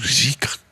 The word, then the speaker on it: dhikr: Les pratiques soufis, ce qu'on appelle dhikr ou zikr, comme on prononce en persan.